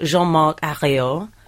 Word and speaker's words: Jean-Marc Ayrault: I was going to say his name, and then I realised I will sound like a middle-class parent trying to teach their child French, but he is called Jean-Marc Ayrault.